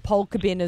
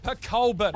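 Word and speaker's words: Pokolbin: I thought it was Polka Bin as well. I used to say Polka Bin, and then apparently it's Pacolbin. – Pacolbin.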